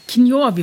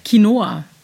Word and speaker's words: Quinoa: Quinoa, wie wird denn das ausgesprochen? – Quinoa, wieder was gelernt, Quinoa. Manchmal lecker zum, warum manchmal nur?